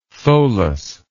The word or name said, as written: tholus